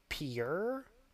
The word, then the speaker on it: piure: I think it's pronounced pure sea squirt. – What? How's it spelled? – P-I-U-R-E.